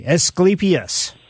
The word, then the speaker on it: Asclepias: How do you spell that? Asclepia? – Asclepias. Asclepias. – Asclepias. – Right.